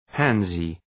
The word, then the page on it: pansy: {‘pænsı}